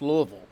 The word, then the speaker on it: Louisville: Make sure you say it right. It is not Louisville. It's like you got a bunch of marbles in your mouth and you just drank a bottle of bourbon. It's Louisville. Interesting squad that you've got here.